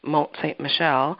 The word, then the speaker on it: Mont St. Michel: It became known as the Mount of St. Michael in French, which is pronounced Mont St. Michel, and this Mount of St. Michael today is a UNESCO World Heritage Site, is visited by millions.